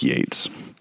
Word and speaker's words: Yeats: He's Celtic origin, so you, you pronounce the E A with just like, like a long A, so it's Yeats.